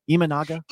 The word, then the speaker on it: Imanaga: Did I say it right, Brad? Imanaga. – Can you? – There we go.